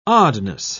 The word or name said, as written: oddness